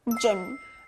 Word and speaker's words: gym: 이 다음 단어도 쥬 소리가 났는데요. 우리가 열심히 운동하기 위해 체육관을 가는데요. 체육관, 영어로 뭐였죠? – Gym. – That's correct. Gym. 쥬 소리 들렸죠?